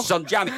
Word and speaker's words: Ehsan Jami: En dan hebben we het natuurlijk over de Iraans-Nederlandse politicus Ehsan Jami. Mooi gezegd, hè? – Goed uitgesproken, toch? – Ehsan Jami.